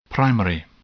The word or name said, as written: primary